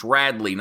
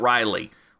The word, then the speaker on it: Radley: And if you can say, hey, dumbass, the dude's name is pronounced like this. It's Radley, not Riley, when it comes to Buki.